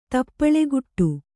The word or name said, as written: ತಪ್ಪಳೆಗುಟ್ಟು